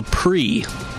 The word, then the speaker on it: Prix: Called The Pri. Spelled P-R-I-X. So you get that French pronunciation like Grand Prix.